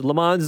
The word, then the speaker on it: Le Mans: And I mean, look, if you don't get what it is, Le Mans is a place in French, Le Mans, I guess I should be pronouncing it.